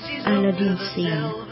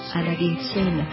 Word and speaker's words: Aladdin Sane: Khi David Bowie hóa kiếp cho Ziggy thì cũng là lúc mà ông đã cho ra đời Aladdin Sane. Aladdin Sane phát âm gần giống như là A Late Insane,